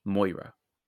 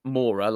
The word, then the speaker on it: Maura: Well, initially, they said Moira. – Because I've wrote down, that's an old woman's name. – Yeah, but it's spelt Maura, like Laura with an M.